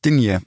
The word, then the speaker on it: Digne: Digne, I like that pronunciation. – I always get him confused with Spagna, who sang Call Me. – That was Spagna. – Yeah, so, but it's pronounced Digne, isn't it?